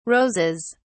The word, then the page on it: roses: British English